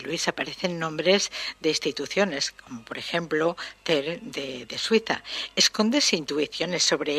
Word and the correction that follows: CERN: CERN, sí, quizá lo haya pronunciado mal.